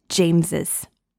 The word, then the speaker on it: James's: James apostrophe s and James apostrophe are both pronounced James's.